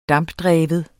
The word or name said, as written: dampdreven